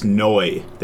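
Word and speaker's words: Neu: Uh, instead of new, it's Noi. They pronounce it Noi.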